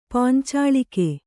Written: ಪಾಂಚಾಲಿಕೆ

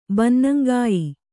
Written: ಬನ್ನಂಗಾಯಿ

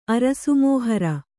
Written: ಅರಸುಮೋಹರ

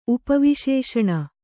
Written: ಉಪವಿಶೇಷಣ

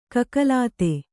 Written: ಕಕಲಾತಿ

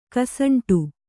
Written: ಕಸಂಟು

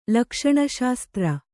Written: ಲಕ್ಷಣಶಾಸ್ತ್ರ

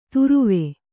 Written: ತುರುವೆ

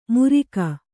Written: ಮುರಿಕ

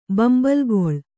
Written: ಬಂಬಲ್ಗೊಳ್

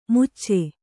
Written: ಮುಚ್ಚೆ

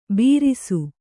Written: ಬೀರಿಸು

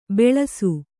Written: ಬೆಳಸು

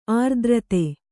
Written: ಅರ್ಥಾರ್ಥಿ

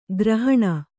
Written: ಕೋರಿಹಣ್ಣು

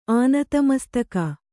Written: ಆನತಮಸ್ತಕ

